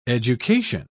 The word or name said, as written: education